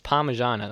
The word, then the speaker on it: Parmigiana: That is Parmigiana. Such an overpronounced mij. All right. Yeah, so one of my arguments here is that it's Parmigiana. That's how we say it in our accent.